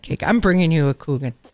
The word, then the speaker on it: Kuchen: Oh, had to go tell me what Coochin, what is it, Coochin? – Coogan. Yeah. – Why don't they spell it like that then? – Or Kuchen. – It's a German word for cake.